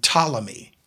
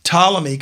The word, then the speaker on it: Ptolemy: And incidentally, of the four generals, one of them was named Seleucid, and one of them was named Ptolemy, and it's PT. The P is silent.